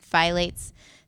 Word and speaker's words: phthalates: Anyways, okay, since this is more solution, since heat can cause plastics to leak BPA and phthalates, if I read it, I want to pronounce the P, into food, avoid microwaving food or beverages, including infant formula and pumped human milk.